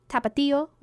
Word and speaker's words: Tapatio: Pick a Dorito flavor, Cool Ranch, Nacho Cheese, Tapatio, I guess that's how you say it. – Yes, that's correct.